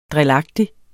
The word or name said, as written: drilagtig